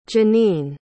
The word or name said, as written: Janeen